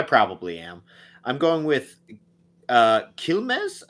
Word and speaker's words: Quilmes: The I would be more of an E sound, Quilmes.